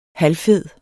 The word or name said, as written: halvfed